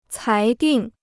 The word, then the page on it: 裁定: cái dìng